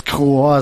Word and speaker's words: Chor: Oder wie spricht man das aus? Du bist der. – Ja, also musst du mal fragen. – Du musst das wissen. – Chor ist richtig.